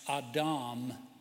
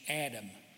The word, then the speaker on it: Adam: And the Hebrew word man is pronounced Adam, and we pronounce it in English Adam.